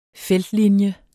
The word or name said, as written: feltlinje